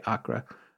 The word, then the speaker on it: Acre: In the 1960s, he shows up in Accra, spelled like acre, but that's how you said to say it, Kinga, so I'm saying it that way, Accra.